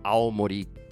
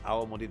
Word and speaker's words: Aomori: A-W-A-M-O-R-I. You have to be careful because if you don't pronounce it carefully, people will think you're talking about Aomori Prefecture.